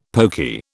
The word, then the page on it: pokey: British English